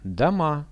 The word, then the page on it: doma: Russian